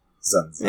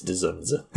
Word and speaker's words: Dzunza: I was looking up how to say his name, Dzunza. You don't pronounce like the D's really, Dzunza. – Yeah. It's Dzunza. Sorry, George.